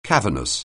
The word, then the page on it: cavernous: anglais